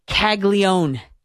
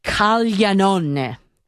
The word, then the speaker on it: Caglianone: The correct pronunciation of the last name, I'm looking at it. It's Caglianone. Caglianone. That's how you say it.